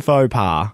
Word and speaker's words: faux pas: How do I say it? – Fashion faux pas. – That's it.